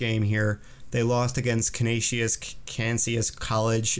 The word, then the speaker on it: Canisius: They only had a one game away series at Constantius. How do you say that? Canisius.